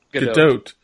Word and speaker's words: Gadot: Gadot.